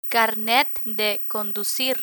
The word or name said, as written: carnet de conducir